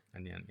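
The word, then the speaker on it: Anagni: And the town of, I think it would be pronounced Agnani. – Agnani, yeah, sounds right.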